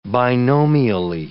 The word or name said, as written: binomially